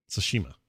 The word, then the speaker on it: Tsushima: Finally, Ghosts of Tsushima or how do you say it? Tsushima. Right? John, yeah. Does that sound right? – Yeah.